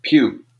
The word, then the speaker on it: Pugh: And then I don't know how to pronounce her last name, but it's P-U-G-H. – Pew. – It is Pew? – Yeah, Florence Pugh.